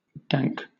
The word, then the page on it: dank: British English